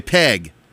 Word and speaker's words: Peg: It's Pig. I'm sorry, a Peg. Peg. P-E-G. Peg. Rhymes with leg.